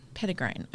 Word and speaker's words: Petitgrain: Petitgrain.